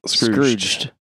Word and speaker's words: Scrooged: Scrooged with a D. – Scrooged. I never pronounce the D.